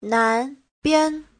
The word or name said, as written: nan2bian5